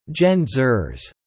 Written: Gen-Xers

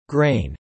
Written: grain

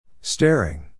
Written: staring

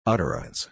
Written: utterance

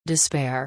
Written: despair

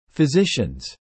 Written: physicians

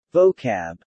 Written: Vocab

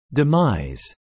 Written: demise

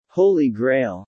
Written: holy grail

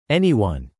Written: anyone

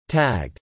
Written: tagged

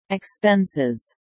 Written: expenses

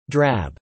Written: drab

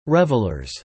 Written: revelers

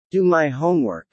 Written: do my homework

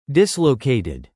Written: dislocated

